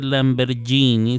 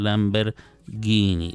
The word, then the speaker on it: Lamborghini: Entonces no se dice Lamborghini, se dice Lamborghini.